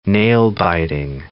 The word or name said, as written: nail-biting